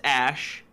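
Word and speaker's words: Æ: The AE is pronounced Ash.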